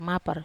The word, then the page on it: mapar: Wamesa